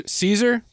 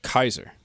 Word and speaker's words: Caesar: Soft C's, Caesar? It's Kaiser. That's where the modern Kaiser comes from.